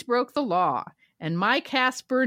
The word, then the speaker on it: Kacper: And it is spelled the Polish way, K-A-C-P-E-R. Our listener wrote in and said, I would like to nominate Casper for Internet Birdie of the Week.